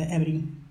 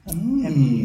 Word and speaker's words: HBNY: However, it's spelled HBNY, which is the Egyptian origin, the Ebony. Ebony, yeah.